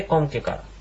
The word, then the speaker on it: コムケカル: クオムケカルという言葉は縮まってコムケカルという発音になっています。